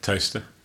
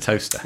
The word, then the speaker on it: Towcester: Toaster. – Yes, and we drove past Toaster to get there. But it's actually written Towcester. – Yes, it is. – But you have to pronounce it Toaster.